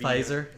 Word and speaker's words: Pfizer: How do you pronounce it? PFIV. – Pfizer.